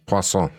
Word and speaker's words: poisson: Poisson. – Poisson, that's right.